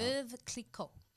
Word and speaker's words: Veuve Clicquot: Veuve Clicquot.